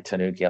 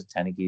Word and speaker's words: Tanuki: People say Tanuki, I think, because of the Mario game. I used to live in Japan, and the only reason I chose Tanuki is because, like, I really like the folkloric Japanese creature, which is, you know, I got used to the pronunciation Japanese people.